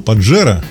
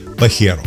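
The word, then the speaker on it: Pajero: И чтобы было уж совсем понятно, в испанской транскрипции слово произносится именно как «баха», а не «баджа» или «байя», так же, как привычный нам Паджера должен произноситься именно как «пахеро». – Извините уж за лексику, но это действительно так звучит по-испански.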